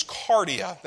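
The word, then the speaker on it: cardia (καρδία): This word heart in the Greek, because we're in Matthew, which is written in Greek, it's in the New Testament. This word heart literally means cardia.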